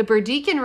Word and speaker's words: Burdekin: They sent the photos to Queensland Museum turtle expert John Cann, who made his own trip to the Burdekin, am I saying that right? – Mm-hmm. – The Burdekin River to spot another turtle and confirm that it indeed was new to science.